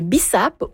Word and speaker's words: bissap: Je parle du jus d'hibiscus qui est souvent appelé le bissap ou le carcadé, si je le prononce bien.